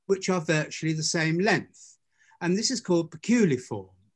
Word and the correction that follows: poculiform: or poculiform. I should say it properly, poculiform.